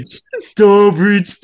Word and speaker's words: Stourbridge: Is that how I say it? Stourbridge? Have I said that wrong? – Stourbridge Pride, yeah.